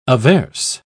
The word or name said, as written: averse